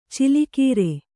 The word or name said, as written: ಛಲಗಾರ